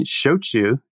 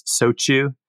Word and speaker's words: shochu: But I hear it mispronounced as sochu, shoju, or soshu. And the one that I actually heard the other day, which was a new one for me, I heard shoku. So the CH became a K.